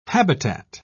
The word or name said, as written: habitat